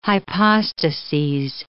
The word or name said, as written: hypostases